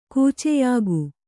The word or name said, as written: ಕೂಚೆಯಾಗು